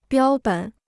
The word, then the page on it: 标本: biāo běn